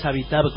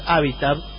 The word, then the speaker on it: Habitat: No es Habitat, como yo decía todo el tiempo en Buenos Aires, sino que es Habitat, que son estos lugares que no sean bien...